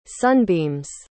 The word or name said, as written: sunbeams